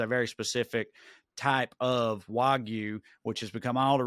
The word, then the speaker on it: Wagyu: Wagyu? – Yes.